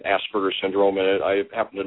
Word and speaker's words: Asperger: So, then I took 4 years of German, so Asperger would be pretty close to the German pronunciation as well.